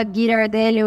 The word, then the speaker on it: Ghirardelli: eu nunca sei se fala girardelli ou girardelli Ghirardelli, vocês sabem a é pronúncia? É Girardelli.